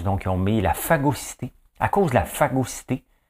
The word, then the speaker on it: phagocyté: C'est à cause de la phagocité par l'ultra-gauche. Hein? La phagocité par ultra-gauche, regardez, ça se prononce comme ça. Phagocité.